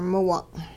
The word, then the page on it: miwung': Hupa